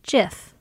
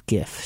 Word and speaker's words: GIF: How do you really pronounce GIF? Or is it JIF? – It's GIF.